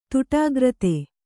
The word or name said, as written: ತುಟಾಗ್ರತೆ